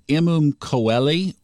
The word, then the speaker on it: Imum Coeli: The IC is pronounced a couple of ways, Imum Coeli or Coeli, and it can also be referred to as the nadir, N-A-D-I-R.